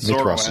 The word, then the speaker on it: Mithras: Basically, you go deep enough and you find Mitra, burst through the wall, transforms into Mithras, god absorbed into the Roman pantheon from Zoroastrianism. I probably didn't pronounce that right, did I? – I think Mithras is right.